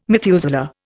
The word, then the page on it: Methuselah: Indian